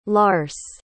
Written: lars

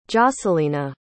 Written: jocelina

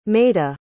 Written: maida

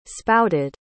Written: spouted

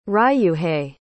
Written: ryuhei

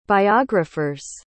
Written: biographer's

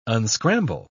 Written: unscramble

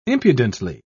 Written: impudently

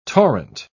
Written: torrent

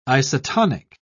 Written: isotonic

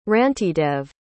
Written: rantidev

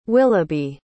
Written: Willoughby

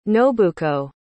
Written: nobuko

